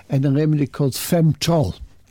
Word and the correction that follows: Femtrol: Femtrol. – Femtrol. – F-E-M-T-R-O-L.